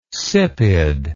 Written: sipid

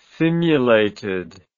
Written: simulated

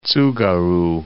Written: Tsugaru Strait

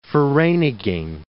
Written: Vereeniging

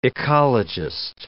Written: ecologist

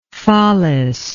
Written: follis

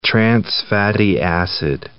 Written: trans-fatty acid

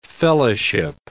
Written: fellowship

